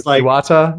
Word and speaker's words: Iwata: Iwata. – No, okay. – I probably added a syllable, but that was just. – Or something, yeah. – I sleepy talking, not mispronunciation. – You mean Satoru Iwata?